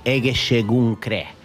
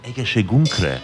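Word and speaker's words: egészségünkre: How do you say in Hungary? So Egeshegedre. Actually, we're going to say Egeshegunkre. – Egeshegunkre.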